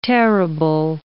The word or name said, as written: tearable